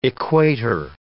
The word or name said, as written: equator